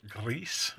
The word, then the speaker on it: Gris: Um, the other game I'm playing, I started last night, is I, have I've heard it is Gris. You've got to roll your R, um, spelled like G R I S.